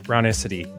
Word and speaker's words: brownicity: I wanted to ask first a bit about brownicity. Did I say that right? Rhymes with ethnicity.